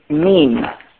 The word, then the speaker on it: meme: We call ideas, when we're speaking technically, an idea is called a meme. M-E-M-E. Rhymes with cream.